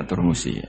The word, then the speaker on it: Turmusi: Kalau sini bacanya Termasi atau Turmusi umumnya? – Termasi atau Turmusi? – Ya Turmusi. Ada yang baca apa?